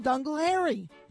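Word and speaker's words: Dún Laoghaire: He's in Dunleary. Which is the pronunciation if you pronounce it the way it looks like it's spelled.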